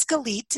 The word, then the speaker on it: Galit: So my name is Galit. It's spelled G-A-L-I-T. Which most people pronounce as Galit, Gillette, Galit, and about every kind of other thing you could think of.